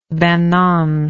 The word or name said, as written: banon